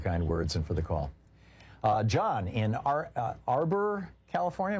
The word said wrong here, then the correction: Auburn: Am I saying it right? – Auburn. Auburn. – Yeah, oh, Auburn. Oh, okay.